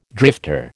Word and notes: drifter: American English

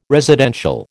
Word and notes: residential: American English

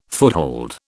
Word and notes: foothold: British English

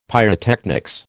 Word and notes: pyrotechnics: American English

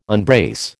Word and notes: unbrace: American English

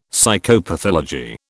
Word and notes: Psychopathology: British English

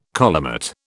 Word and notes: collimate: British English

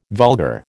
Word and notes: vulgar: American English

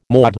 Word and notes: maud: American English

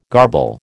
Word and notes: garble: American English

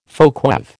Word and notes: folkweave: American English

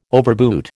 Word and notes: overboot: American English

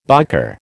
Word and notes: biker: American English

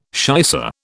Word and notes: shicer: British English

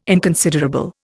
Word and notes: inconsiderable: Indian

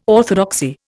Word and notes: orthodoxy: Indian

